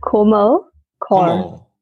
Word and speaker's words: Komal Kaur: It's Komal Kaur. – Komal Kaur. – Kaur. Right. Like C-O-R-E, Kaur.